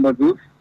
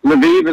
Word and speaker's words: Lviv: In Russian, it's called Lvov. I found that out. It's kind of a strange name, but Lviv is how we would pronounce it here in America.